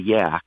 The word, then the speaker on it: Yaak: There's a place in Montana called a Yaak, and it's spelled Y-A-A-K, and it's just so remote, and it's pristine.